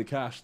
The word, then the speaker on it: Ka5sh: So it's supposed to be Ka5sh the 5 is Silent, but it's just Ka5sh.